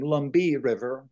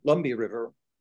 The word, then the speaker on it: Lumbee: And so that became, if you pronounce it in the way it would have been pronounced there, instead of Lumber River, it became Lumbee River, Lumbee River.